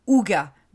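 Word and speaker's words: hygge: En réalité, la décoration igue se prononce Ouga,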